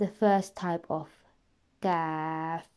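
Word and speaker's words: Kaf (ك): It is very important to remember from the previous lessons that one letter is pronounced as Qaf because it is a full mouth heavy letter, and this one that we are studying at the moment is pronounced as Kaf because it is empty mouth letter.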